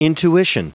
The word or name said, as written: intuition